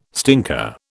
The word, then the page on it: stinker: British English